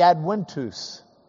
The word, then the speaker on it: Adventus: But the Latins would pronounce the V as a W, so it'd be Adventus.